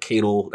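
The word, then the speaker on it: Caiatl: And I was excited because, you know, I didn't see the whole Keitel, that's the pronunciation of her name.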